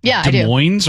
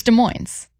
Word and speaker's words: Des Moines: Is the 253 going, it's pronounced, but then they spell it the way it's spelled. So it really could go either way. It's Des Moines.